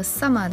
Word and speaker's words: summer: Shall I 连读， compare 和 summer 的结尾都是 R，在英音中不用卷舌，然后 thee 的发音要咬准。